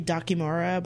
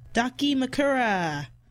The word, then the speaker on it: dakimakura: I used to say Dakimura, but it was always wrong. Dakimakura!